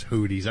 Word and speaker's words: Houthi: I think it's Houthi. – Houthi, yeah, like the blowfish.